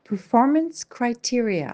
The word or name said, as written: performance criterias